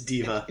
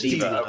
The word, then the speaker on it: D.Va: It's D.Va. – It's just D.Va.